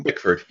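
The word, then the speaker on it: Bickford: I think there was, yes, on the 14th of September, Lovett Binkford, I think that's how you pronounce it, and I'll put the link in the room. – Binkford, yeah.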